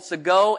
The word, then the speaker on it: Segoe: Because a lot of the fonts, for example, Segoe, S-E-G-O-E is our font.